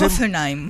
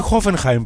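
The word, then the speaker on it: Hoffenheim: Χόφενχάιμ. Δεν χρειάζεται να βάλεις προφορά, με τη Χόφενχάιμ παίζει, είναι απλά. – Α, Χόφενχάιμ.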